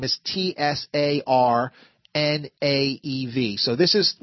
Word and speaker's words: Tsarnaev: like Tsar, like a Russian Tsar, right. – Aev. So this is what he would sound like. – Johar Tsarnaev.